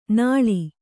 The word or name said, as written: ನಾಳ್